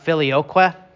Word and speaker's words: filioque: That phrase "and the Son" in Latin, that's pronounced filioque.